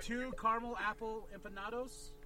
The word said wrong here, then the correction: empanadas: Right? Did I say that? Empanadas. – Empanadas.